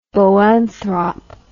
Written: boanthrop

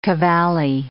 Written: cavally

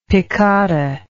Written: piccata

